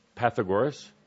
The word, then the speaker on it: Pythagoras: Pythagoras. Pythagoras, who is a philosopher, lived about Pythagoras.